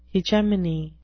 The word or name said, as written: hegemony